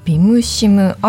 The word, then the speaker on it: BIMCIM: はい、設計や調査結果などについては業務の効率化を図るためにビムシムといった取り組みも行っているところです。 – はい、ビムシム、アルファベットで書くと BIMCIM でビムシムと読むんですね。